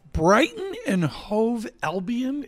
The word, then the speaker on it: Brighton and Hove Albion: Brighton and Hove Albion, is that how you pronounce that? – That's right.